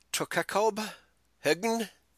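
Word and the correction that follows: Tokkekøb Hegn: How's that pronounced? Tokakob Hegn. – It is Tokenkøb, Hegn, Northern Zealand in Denmark, and Zealand is the island where Copenhagen is located.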